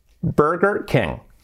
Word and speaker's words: Burger King: Burger King. Burger, la R ahí, letra clave donde las haya, Burger King. Y nada de King, King. La E como una E, King. Burger King.